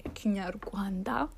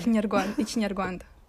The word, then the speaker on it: Kinyarwanda: Okay, Kinyarwanda, because that's how you say it. – Say it again. – Kinyarwanda.